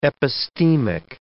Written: epistemic

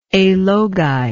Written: alogi

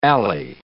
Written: alley

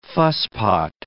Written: fusspot

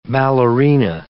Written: ballerina

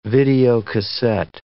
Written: videocassette

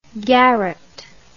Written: garrot

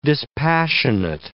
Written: dispassionate

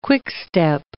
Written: quickstep

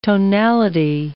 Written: tonality

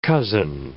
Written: cozen

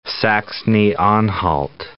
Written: Saxony-Anhalt

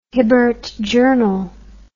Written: hibbert journal

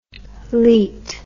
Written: leat

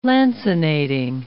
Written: lancinating

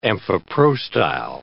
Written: amphiprostyle

